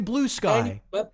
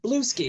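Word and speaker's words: Bluesky: Or as I like to call it, Blue Ski, because it looks like it should rhyme with Brewski.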